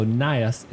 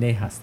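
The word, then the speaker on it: Najas: The J would be a Y sound, so Nias is correct.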